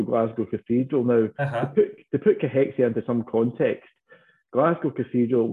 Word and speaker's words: cachexia: But it's C-A-C-H-E-X-I-A is how you spell it. And the correct way to pronounce it is cachexia, but a lot of people will drop that the second C.